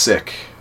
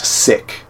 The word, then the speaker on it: Sikh: It's pronounced Sikh. – Look at the stuff I'm learning tonight. Okay. North Americans, by default, we call it Sikh, but it's actually pronounced sick.